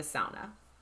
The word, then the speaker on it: sauna: Well, I was thinking particularly of the sauna. – Yeah, the sauna. And I need to keep learning to say it right. Sauna. it's like a sow, sauna.